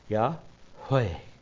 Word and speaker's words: Yahweh: There are none in the Tetragrammaton and Yahweh, and it says, really, if you pronounce it correctly, it goes, Yahweh. The inhale is, Yah. Exhale is, Weh.